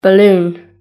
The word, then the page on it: balloon: British English